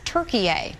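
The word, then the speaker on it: Türkiye: Officials have notified the United Nations, asking that the country be referred to as Turkiye, the way it's spelled and pronounced in Turkish.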